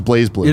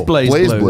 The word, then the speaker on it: BlazBlue: Also interesting, BlazBlue, pronounced BlazBlue. – It is BlazBlue.